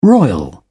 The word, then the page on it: royal: British English